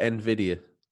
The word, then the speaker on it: Nvidia: You call it Nvidia? A lot of us call it Nvidia. – Nvidia? Thank you for the correct pronunciation.